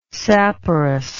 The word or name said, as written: saporous